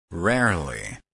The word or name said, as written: rarely